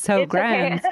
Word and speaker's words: Sjögren's: Sjögren's.